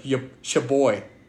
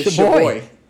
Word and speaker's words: schabowy: Poland, national dish is called, it's spelled S-C-H-A-B-O-W-Y. So it sounds like shaboy. – Shaboy? – It's shaboy.